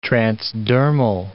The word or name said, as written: transdermal